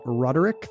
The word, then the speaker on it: Roderick: John was at John Roderick, three syllables, on both Twitter and Instagram.